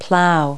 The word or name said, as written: plow